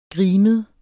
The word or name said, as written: grimet